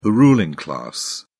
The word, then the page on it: ruling class: British English